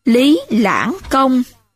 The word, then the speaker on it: Lý Lãng Công: Lý Khuê chiếm giữ đất Siêu Loại, nay thuộc huyện Thuận Thành, tỉnh Bắc Ninh, và xưng là Lý Lăng Công, xin lỗi, và xưng là Lý Lãng Công.